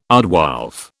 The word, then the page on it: aardwolf: British English